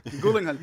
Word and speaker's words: Gyllenhaal: So Zodiac was really good, and had Downey Jr. and Jake Gyllenhaal, and I like how you pronounce Jake Gyllenhaal's last name. It's like he's a ghoul. – Gyllenhaal.